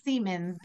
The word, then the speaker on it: Siemens: And it is, yes, it's pronounced Siemens, but it's not spelled like semen.